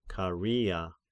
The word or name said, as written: Karia